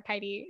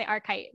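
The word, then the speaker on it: Arcite: And he was like, it's either Arkite or Arkite, depending on the verse. And I was like, that's annoying. He's like, yeah, I say Arkite.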